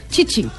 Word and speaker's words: Tite: De ¿Cómo se dice Tite en portugués? – Chichi.